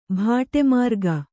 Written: ಬಾಟಾಮಾರ್ಗ

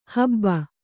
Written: ಹಬ್ಬ

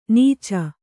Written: ನಾಚ್

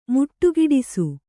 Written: ಮುಟ್ಟುಗಿಡಿಸು

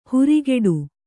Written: ಹುರಿಗೆಡು

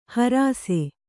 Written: ಹರುಸ

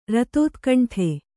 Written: ರತೋತ್ಕಂಠೆ

